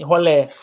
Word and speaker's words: rolé: Rolê, hein? Rolé. Não é rolê. Como é que fala? – É, não? Né? rolé, irmão. Rolé. Rolê é gola. Gola rolê. Entendeu?